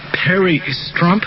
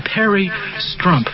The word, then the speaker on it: Perry Strump: Perry Strump. – Huh? – Perry Strump. – Very done. Strump. – Yeah, okay. – It rhymes with hump.